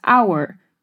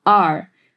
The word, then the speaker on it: our: Él dice nuestros planes para el postre nuestros es our, pero es aún más común pronunciarlo como are. Así que es importante saber ambas formas de pronunciarlo.